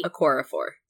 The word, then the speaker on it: Okorafor: Okorafor.